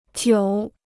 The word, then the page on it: 久: jiǔ